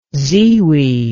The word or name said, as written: ziwiye